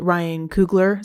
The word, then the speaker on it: Coogler: Ryan Coogler, is that how you say his name? – Yes.